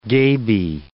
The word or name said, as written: gaby